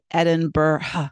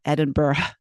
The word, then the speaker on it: Edinburgh: Well, it's it's it's actually the G is silent and the H isn't. So it's really Edinburgh, Edinburgh. But the, you know, you say it, you say, Edinburgh, Edinburgh. So it's not burrow, it's burra.